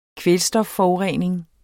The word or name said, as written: kvælstofforurening